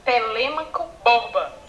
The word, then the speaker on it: Telêmaco Borba: do Telêmaco Borba.